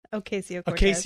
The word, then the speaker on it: Ocasio-Cortez: And of course, this is Alexandria Ocasio-Cortez. Did I say that right? – Ocasio-Cortez.